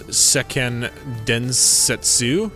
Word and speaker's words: Seiken Densetsu: So, Trials of Mana is a 3D remake of the hit Japanese classic RPG released in 1995 as Seiken Densetsu 3. I remember butchering that name once before. – I think you got it right this time, though.